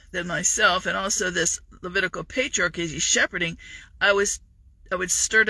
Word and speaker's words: Levitical: Critical rhymes with Levitical. That's how you can tell the law in modern day Christianity. Critical, Levitical.